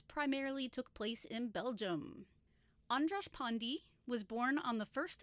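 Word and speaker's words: András Pándy: So according to the pronunciations that I looked at, it's Andras Pandy.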